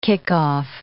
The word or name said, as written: kickoff